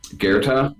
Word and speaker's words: Goethe: It's called Goethe? Is how you pronounce that? – Goethe, the German Goethe.